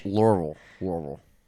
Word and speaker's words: Louisville: Louisville.